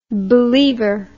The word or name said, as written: believer